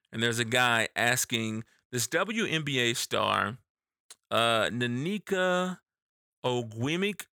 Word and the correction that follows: Nneka Ogwumike: and I'm so sorry because I know I butchered that name. Nanika, it's Nneka Ogwumike.